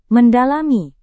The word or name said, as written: mendalami